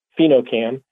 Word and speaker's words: PhenoCam: We are part of a network called PhenoCam, which Pheno is spelled P-H-E-N-O, and then C-A-M, PhenoCam.